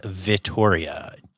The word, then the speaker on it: Vittoria: So thank you, Vittoria. And in case y'all are wondering, her name is Vittoria. Two T's, not like Victoria. So I'm not saying it wrong.